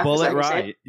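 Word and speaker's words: Bulleit Rye: Bullet Rye. You were saying it right.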